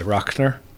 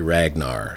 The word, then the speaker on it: Ragnar: And he spells his name R-A-G-N-A-R. – Yeah. – But they're from a little further west of Oslo, and so they pronounce it Rochner. – Okay. – And I didn't want anybody calling my whiskey Ragnar, so I spelled it phonetically.